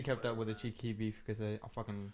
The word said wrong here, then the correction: Chief Keef: Chief Keef, bro. Pronounce Chief it. Keef. Four letters. Chief Keef. – Not Chief Keef. – There you go.